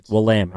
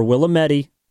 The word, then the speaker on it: Willamette: Willamette. All right. So just keep in mind Willamette University for Evan Giddings' collegiate career. – We also take Willamette or Willametti. It's often mispronounced.